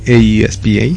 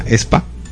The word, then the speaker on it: aespa: El primero, el grupo no se llama AESPA, de hecho se llama ESPA. – ESPA, sí, o sea, se pronuncia, o sea, así, ESPA. – Se escribe a ESPA y se pronuncia ESPA.